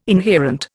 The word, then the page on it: inherent: Indian